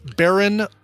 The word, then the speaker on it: Beren: This is a singer by the name of Beren Olivia, and it's B-E-R-E-N. So neither of those vowels in the way that you might think Beren is spelled. B-E-R-E-N Olivia.